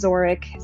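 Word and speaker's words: Wzorek: And then my last name is Zorik, starts with a W, so it's W-Z-O-R-E-K.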